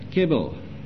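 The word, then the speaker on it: chebel: This Hebrew word is a Hebrew word, excuse my pronunciation please, but it's C-H-E-B-E-L, kebel.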